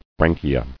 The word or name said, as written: Branchiae